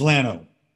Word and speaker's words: Llano: And, but Llano is Lano.